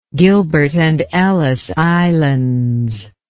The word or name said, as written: gilbert and ellice islands